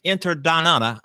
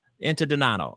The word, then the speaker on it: Interdonato: I want to thank you, Kim Interdonata. How do you pronounce your last name? It's called Interdonato. – Interdonato.